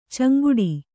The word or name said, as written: ಚಂಗಾಟ